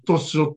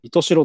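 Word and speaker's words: 石徹白: 石に、通る、徹夜の徹に、ホワイトの白ですね。 – ね、それで愛しおって読むね。 – 糸代と読みます。はい。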